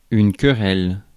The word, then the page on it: querelle: France; [yn kə.ʁɛl]